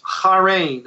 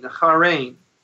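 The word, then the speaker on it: harein (χαίρειν): So, for instance, the greeting of that day, if I could explain it for a minute, in Greek, it was a word which would be pronounced like this, harein, harein.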